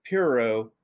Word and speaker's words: Pyrrho: So the pronunciation is Pyrrho and Pyrrhonism.